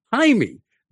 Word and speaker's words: Jaime: That means J-A-I is pronounced hi. What is Jamie Summers' first name? And how is it spelled? It's spelled J-A-I-M-E. It's not J-A-M-I-E. – Right. – J-A-I-M-E. – Right. – Therefore, her first name really is Hymie.